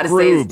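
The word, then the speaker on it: Skroob: And they literally are trying to steal it because Mel Brooks, who is President Snoop, I don't even know how Scroob. – To say it. – Scroob. – Stroop. I'm like saying it wrong. Stroop. I'm going to still say it. – Scroop. It's the letters of Brooks mixed up.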